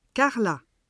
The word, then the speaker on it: Carla: Je m'appelle Carla. – Tu t'appelles Clara ? – Non, Carla. C-A-R-L-A.